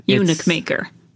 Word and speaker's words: Eunuch Maker: Unuk Mocker, and I think I'm saying that correctly. – Eunuch Maker. – Eunuch Maker. Oh, Eunuch Maker.